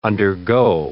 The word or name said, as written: undergo